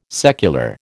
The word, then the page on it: secular: American English